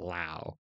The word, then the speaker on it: Lowe: Actually, apparently it's pronounced Lau in his case.